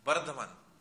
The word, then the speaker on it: Bardhaman: So having left Vrindavan for Navadvip, Srila Jagannath Das Babaji Maharaj arrived in a place called Burdwan. How do you pronounce it? Burdwan? near Calcutta? Bardhaman. Bardhaman. Okay. So it's Burdwan in English. Okay. We'll call it as Bardhaman.